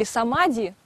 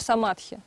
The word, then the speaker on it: самадхи: Если мы будем произносить его на пали, или как его произносят приверженцы тхеравады, то они могут говорить или самади, да, или вообще самадхи чаще всего.